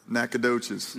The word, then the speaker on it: Nacogdoches: Nacogdoches. – Nacogdoches.